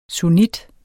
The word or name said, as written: sunnit